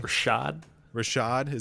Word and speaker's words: Rashad: I think that's how you say his name. – Rashad. – Is it Rashad? – Yeah. Seems like a Rashad.